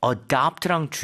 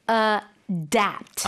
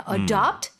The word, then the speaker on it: adapt: You have to be very careful with the pronunciation. – Right. – You have to say adapt. – Adapt. – Yeah. – A-D-A-P-T. 스펠링도 다르죠. – Yeah. Adopt. Adapt.